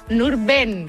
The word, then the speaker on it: Nurben: Yo creo que te falló una vocal. A ver, repítelo. – ¿Nurdin? – Nurben. – Nurben, como suena. – Y además es muy curioso porque, no, tal como se escribe, porque no hay ninguna I, es Nurben Yesef.